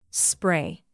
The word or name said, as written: spray